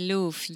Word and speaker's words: Louvre: But it has an R and an E at this. I can't say it with confidence because I want to say the Louvre. – No, but you don't pronounce the R and the E.